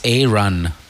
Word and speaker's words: Arun: It's A-Run. – Yeah. – That's how A-R-U-N is Aaron. – That's how you say his name. That's how he says his name.